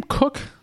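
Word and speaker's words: Koch: Longtime Ravens punter, is it Sam Cook? Is that how you pronounce it? – It is. Did you miss the one above, on the Jordan Addison? – Yeah, we'll do that after.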